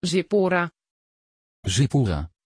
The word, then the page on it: Zippora: Dutch